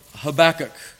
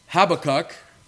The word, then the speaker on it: Habakkuk: Well, you can say Habakkuk, you can say Habakkuk, or you can try to get a little closer to the original and say Habakkuk.